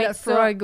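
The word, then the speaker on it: Laphroaig: comme ça qu'on prononce ça? – Ben, lui, il prononce un petit anglo, parce que c'est Lafroig, ouais.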